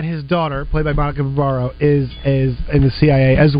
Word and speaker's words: Barbaro: Is it Barbaro? – Barbaro, I believe is how it's pronounced. – Barbaro, yes.